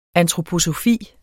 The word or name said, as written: antroposofi